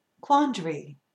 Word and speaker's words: quandary: And also quandary. Quandary, three syllables, quandary. What you probably hear when I speak the word, two syllables.